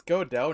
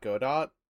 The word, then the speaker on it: Godot: Wait, it's Godot, not Godot? – The T is silent, yes.